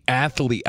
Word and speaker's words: athlete: He's a great athlete. I get it. He can't throw a baseball. His mechanics are off. – Did you put 3 syllables in athlete? – That's how we do it in Jersey.